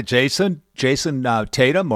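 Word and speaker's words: Jayson Tatum: But Joey, what I mean, is it just come down to Jason Tatum, or what do you think? – Yeah, you said it right.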